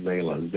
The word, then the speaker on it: Zalah: And if someone wants to get a hold of me, my name is spelled Zalah, Z-A-L-A-H, Z-A-L-A-H.